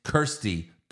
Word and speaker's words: Kirstie: It's Kirsty. Kerr.